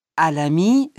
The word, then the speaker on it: Alami: Bonjour, je m'appelle Djibril Alami. Alami, ça s'écrit A-L-A-M-I. – A. – Moi, c'est Alvaro Lopez. Lopez, ça s'écrit L-O-P-E-Z.